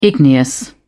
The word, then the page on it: igneous: British English